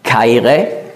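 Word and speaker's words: Chaire (χαῖρε): Also anstatt zu sagen Chaire, eigentlich das CH, das Chi hat man K ausgesprochen, Chaire, sagt er Charis.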